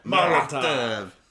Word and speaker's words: Molotov: Tokyo by throwing Molotov cocktails. – Molotov. – Did I say it wrong? – Yeah. – Oh, you got the German pronunciation. – Molotov.